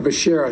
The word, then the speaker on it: Becerra: For Secretary of Health and Education Service, I nominated Javier Baccarria. You know, Javier Becerra, excuse me. – Yeah, strike two. Javier Baccarria, Javier Becerra.